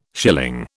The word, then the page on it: schilling: British English